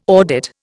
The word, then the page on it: audit: Indian